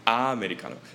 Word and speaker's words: 아메리카노: Right, 우리 어라고 해요. Like UH, Americano. – Yeah. – 근데 사실 한국식으로 아 해야 돼요. – 아메리카노.